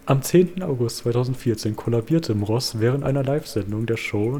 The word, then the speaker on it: Mross: Also das ist übrigens Stefan Moros. Ähm, seit 2005 moderiert Moros. Mor das ist ein scheiß Name. Wie kann man den aussprechen? – Also er heißt M. – Ross, aber zusammengeschrieben. Mross. – Mross.